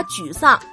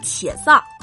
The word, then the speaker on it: 沮丧: 就像气质优雅的学芭蕾舞出身的刘诗诗，就曾在《步步惊情》片花中把沮丧读成了且丧。